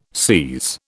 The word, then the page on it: SIS: British English